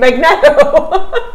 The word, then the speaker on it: Magneto: Typically pronounced as Magneto.